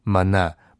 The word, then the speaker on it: 玛纳: 希伯来文的发音就是玛纳，玛纳。